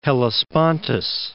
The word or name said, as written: Hellespontus